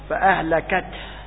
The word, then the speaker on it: فأهلكت: عندما ننطق فأهلكت، وهذا أفضل لفظ يبين خفاء الهاء، إذا ما نطقتها من الممكن أن يلتبس الشدة في التاء وفك النفس فيها، فك الشدة في التاء، فك الشدة في التاء بعد الانتهاء من نطق الحرف يلتبس بنطق الهاء، فأهلكت، يقول فأهلكت ولا ينطق الهاء، مما إن وقفت عشان من أجل أن تحقق الهاء لابد أن تضغط على مخرج الهاء حتى تظهر، فأهلكت، هكذا يتحقق الهاء.